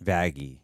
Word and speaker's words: Vaggie: Well, what is it? Vaggie or Vaggie? – Vaggie. – Vaggie. – Yeah. – Hard G.